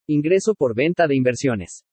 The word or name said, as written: INGRESO POR VENTA DE INVERSIONES